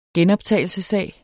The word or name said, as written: genoptagelsessag